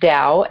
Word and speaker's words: Tao: My brother's name is Tao, T-A-O, pronounced Dao, and my sister's name is Chi, C-H-I, so we are Dao, and Chi.